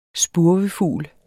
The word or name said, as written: spurvefugl